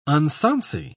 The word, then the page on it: unsonsy: British English